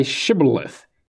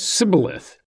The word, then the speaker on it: Shibboleth: When he said no, they said to him, "Then say Shibboleth." And he said Sibboleth, for he could not pronounce it right.